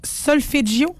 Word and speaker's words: solfeggio: La fréquence sacrée, là, tu m'as parlé de la fréquence solfeggio. Est-ce que je le prononce bien? – Solfeggio, c'est parfait.